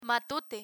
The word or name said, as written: matute